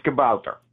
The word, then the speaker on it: kabouter: I could have sworn it was kabooter, kabooty. – No, we pronounce the O-U as ow. So it's kabouter.